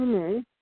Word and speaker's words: Nee: It's Kimberly Nee. – And that's N-E-E.